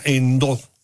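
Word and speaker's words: Endhó: El retiro del lirio acuático en la presa Endo, con H intercalada y acento en la O, va a beneficiar a más de 21,000 habitantes en al menos 3 municipios del estado de Hidalgo.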